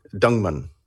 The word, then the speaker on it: Dingemans: And then the family name, D-I-N-G-E-M-A-N-S. Now, I'm assuming because of the French pronunciation, the S is silent. So, Dingman.